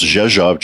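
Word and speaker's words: Rzeszów: And she smiles and tells me the gate and says it's pronounced Zhezhov, just like it's spelled.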